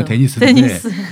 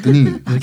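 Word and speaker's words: Denis: 영어로 그렇죠. – 데니스인데 드니 캐나다 사람인데 퀘벡 출신이라.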